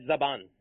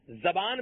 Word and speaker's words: زبان: تلفظ کے اعتبار سے ابھی ابتدا میں ہی عرض کر دوں، زبان بھی پڑھا جا سکتا ہے، زبان بھی پڑھا جا سکتا ہے، کلاہما جاز، دونوں طریقے سے پڑھنا درست ہے، اور معنی بھی اس کا ایک ہی بنتا ہے.